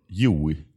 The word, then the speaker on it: Joey: Hur uttalar du ditt namn hemma? – Eh, hemma uttalar jag det eh, Joey. – Bara Joey? – Det är mitt, eh, mitt smeknamn eh, hemmavid. Det är ett isländskt smeknamn för Johannes.